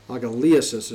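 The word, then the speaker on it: Agaliasis (ἀγαλλίασις): I always get the accent wrong. Agaliasis. Agaliasis is the proper pronunciation.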